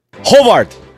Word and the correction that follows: Horvat: Yeah, just one R. Just one R. – Hovart. – Horvat. – Horvat.